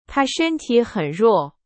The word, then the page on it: 弱: Chinese